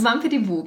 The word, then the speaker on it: Wampedibu: Wampedibu, oder wie spricht man es richtig aus? Wampedibu, genau.